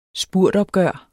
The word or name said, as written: spurtopgør